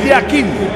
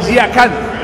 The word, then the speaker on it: Yakin: En el suizo se pronuncia así: Murat Yakin. En turco, Yakan. Cambia la última parte de su apellido.